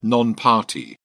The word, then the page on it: non-party: British English